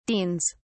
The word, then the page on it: deans: British English